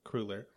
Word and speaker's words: cruller: Cruller? – I was corrected the last time I was in Canada. I'm like, can I get a cruller? And they're like, cruller.